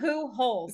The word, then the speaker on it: Pujols: It says Pujols. – It says Pujols. – Dash holes.